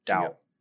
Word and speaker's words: Dou: D-O-O? – D-O-U. Dow. – Oh, Dow. Okay.